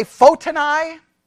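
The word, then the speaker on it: Photini: Some say Fotini. I say Fotini. That's what I'm going with because I'm in Texas and we're right, however we say whatever. Okay. But it's Fotini.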